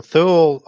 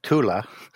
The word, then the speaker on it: Thule: So Thule, also pronounced Tula, as it comes out of, in case people are using one or the other sort of pronunciations, was an Atlantis-like equivalent or an Asgard-like equivalent of Norse mythology.